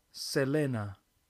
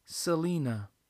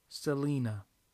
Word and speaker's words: Selena: Bueno, en español, la letra E siempre suena igual. 100% del tiempo se pronuncia E. Entonces, en español se pronuncia Selena. Pero como Selena es estadounidense, ella nació en Estados Unidos, sus familiares pronuncian su nombre Selena. En este caso, la letra E se pronuncia en inglés. E, Selena.